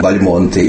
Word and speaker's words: Бальмонт: У него были братья, у него ныне живущие Бальмонты или Бальмонты. Как быть? И здесь я бы сказал так, что фамилия произносится Бальмонт. Она происходит от, видимо, от литовского «балмут», ударение на первом слоге.